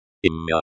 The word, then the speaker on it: имя: Имя is pronounced EMYA.